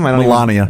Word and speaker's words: Melania: Yeah, you're the Malia Trump of. Is that how you say your name? – Melania. – What is it? – Melania.